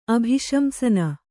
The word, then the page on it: ಅಭಿಶಂಸನ: kannada